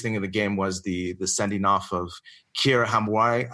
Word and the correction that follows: Hamraoui: Kira Hamraoui. – Yeah, Hamraoui.